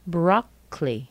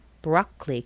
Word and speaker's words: broccoli: Anytime you see C-A-L-L-Y in American English, you'll pronounce it cle. Broccoli. Broccoli. Can you hear that second O? Neither do I.